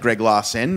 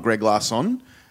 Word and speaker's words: Greg Larsen: I actually did respond to someone just saying, like, sorry, my name is spelled E-N, not O-N, like Greg Larsen, not Greg Larson.